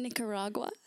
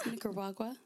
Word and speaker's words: Nicaragua: Ni- Nicaragua. Nicaragua.